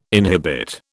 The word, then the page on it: inhibit: British English